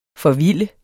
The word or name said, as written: forvilde